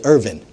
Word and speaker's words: Irwin: Irwin, though he called himself, that's the way he pronounced it, Irvin, like it's E-R-V-A-N. Amen. Or V-I-N.